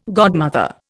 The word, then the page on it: godmother: Indian